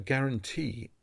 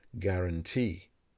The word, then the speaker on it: guarantee: A guarantee is a written promise by a company to replace or repair a product free of charge. The letter U in this word is silent. Guarantee.